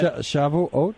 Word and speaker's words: Shavuot: Shavuot, is that, do I say it right? – Shavuot. – Shavuot.